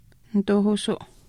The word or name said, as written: nituuhuusuu